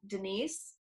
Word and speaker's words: Denise: On Instagram, you can find me at Denise, D-E-N-I-S-E, Sells, Oklahoma.